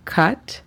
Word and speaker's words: cut: Cut significa cortar. La pronunciación fonética de esta sería C-A-T, cut. El segundo verbo es fly. Fly significa volar. La pronunciación fonética de este sería F-L-A-I, fly.